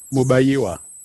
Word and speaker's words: Mubayiwa: Bruce Moo, just tell me. – Mubayiwa.